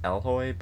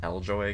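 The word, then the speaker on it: Eloy: Okay, so it's pronounced Elhoy, but I just want to say Eljoy, because that's the way it's spelled, and it kind of sounds Game of Thronesy.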